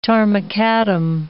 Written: tarmacadam

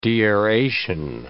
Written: deaeration

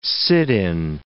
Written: sit-in

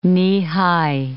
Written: knee-high

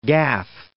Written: Gath

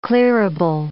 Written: clearable

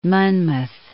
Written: Monmouth

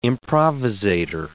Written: improvisator

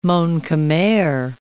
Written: Mon-Khmer

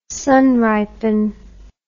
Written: sunripen

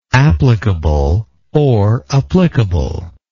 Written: applicably

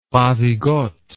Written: ravigote

